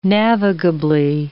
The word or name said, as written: navigably